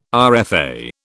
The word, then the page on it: RFA: British English